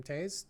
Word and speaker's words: Toews: So, and for anyone who wants to read the book, it's also called Women Talking, a Novel by Miriam Taze. Taze is spelled T-O-E-W-S.